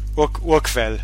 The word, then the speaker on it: Urquell: How would you say that in German? – Urquell. Pilsner Urquell.